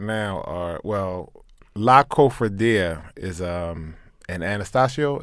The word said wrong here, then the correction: Atanasio: is that how you say it? Atanasio? – Yeah, Atanasio.